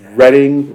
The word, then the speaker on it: Reading: I was down in Reading, Pennsylvania a couple of weeks back, and I said Reading. They went, yeah, y'all not from around here, is ya? And they let me know, and I apologize. I got the name wrong, but it's Reading.